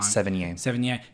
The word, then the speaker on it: Sevigny: And I, Chloe, and I forget, I was going to say her last name wrong. – Sevigny. – Chloe Sevigny, she's a powerhouse in it.